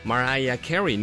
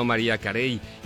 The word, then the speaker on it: Mariah Carey: hasta la pronunciación, por eso es Mariah Carey, no María Carey.